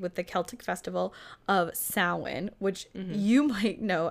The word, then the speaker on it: Samhain: It's pronounced Samhain, which means summer's end, and this festival marked the end of the summer harvest and the start of the dark winter.